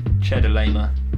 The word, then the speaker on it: Chedorlaomer: Yeah, I think Chedorlaomer. – I think you got it first time, for sure, but that's a confusing word.